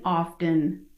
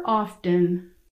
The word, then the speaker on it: often: For instance, I grew up in New England, where we pronounced the R in February and the T in often.